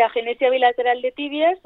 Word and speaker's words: agenesia bilateral de tibias: tú naciste con agenesia bilateral de tibias, ¿lo he dicho bien? Sí, sí, agenesia bilateral de tibias,